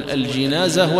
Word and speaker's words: الجنازة: وسبق معنا في بداية شرحنا لكتاب الجنائز أن الجنازة تنطق بالكسر وبالفتح، تقول الجنازة والجنازة. وذكرنا هناك أن نطقها بالكسر يعني النعش، يعني النعش يعني السرير الذي يحمل عليه الميت، وأنها بالفتح هي الميت نفسه.